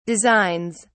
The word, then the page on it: designs: British English